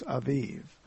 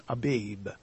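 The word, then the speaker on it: Aviv (אביב): because bees in Hebrew, the baith, they're more oftenly translated as a soft bee. So it almost sounds like a V, so it's Aviv. And that is much easier to pronounce than Abib. So these, we'll probably read them properly here, but Aviv is the real pronunciation. Soft description, the soft pronunciation of the Beth.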